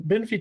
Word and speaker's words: Benfey: But I'm thinking, I'm not sure I pronounced the name, Christopher Benfey. Is that right? – Benfey, you know? Yeah, I think, yeah, yeah.